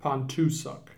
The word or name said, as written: Pontoosuc